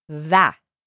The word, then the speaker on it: و: و is of course the word for and. و. A lot of times in conversation, you'll hear this said as o. But it's spelled the same way, and just, again, the difference between preference and pronunciation. So in this poem, it's va.